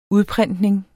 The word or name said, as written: udprintning